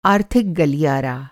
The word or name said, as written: गलियारा